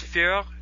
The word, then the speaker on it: Furrer: You mentioned, I pronounce it one more time, it's Führer. Führer. – Führer, F-U-R-R-E-R. – F-U-R-R-E-R. Okay.